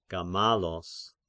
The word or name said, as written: Gamalos